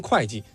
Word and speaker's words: 会稽: 这个会稽就跟现在会计这俩字是一样的，那叫会稽。就是说当时秦对于文吏的要求，就首先你能写字儿，第二个你能算东西。所以你看这个会稽现在我们念成会稽，但它本意其实都没变，可能当时也念会。